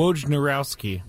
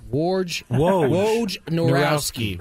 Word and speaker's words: Wojnarowski: Wojnarowski. – Wojnarowski.